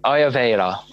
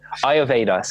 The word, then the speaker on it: Ayurveda: Ayurveda? – Ayurveda. – Ayurveda. – Yeah, I don't know why I can't pronounce it. Ayurveda.